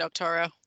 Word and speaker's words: Octaura: O-C-T-A-U-R-A? Octora? – Yeah, I would pronounce it Octora.